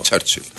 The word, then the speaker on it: Churchill: Churchill. – Το Ψάλτη, ο οποίος με κορόιδευε εμένα που έλεγα για τον Winston Churchill, και όταν άκουσε ότι αυτή είναι η σωστή προφορά, η Οξφορδιανή, έβαλε την ουρά στα σκέλια.